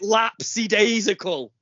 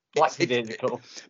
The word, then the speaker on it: lackadaisical: You do not say it lapsidaisical. – Lapsidaisical. People mispronounce it then. – Lackadaisical. I think to be fair to you, people do throw an extra S in that doesn't actually exist.